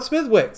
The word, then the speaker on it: Smithwick's: It's not Smithwick. – What is it then? – Smithwick. You don't pronounce the W.